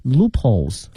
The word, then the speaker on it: loopholes: 好，那么这里面又提到一个词叫 loop 和 hole, 那么其实在发音的时候呢叫 loopholes, 它可以把那个 hole 呢略微的给它吞一下，这个 loop L-O-O-P H-O-L-E 表示漏洞哈，